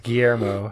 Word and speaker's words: Guillermo: I think it's Guillermo. – Guillermo? – Yeah. – I don't know how to say it. – Guillermo. Guillermo. – One more. – Guillermo. – Del Toro. – Del Toro. – That came from the back of the throat.